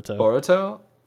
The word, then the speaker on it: Boruto: Boruto.